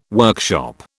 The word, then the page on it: workshop: British English